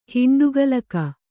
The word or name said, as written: ಹಿಂಡುಗಲಕ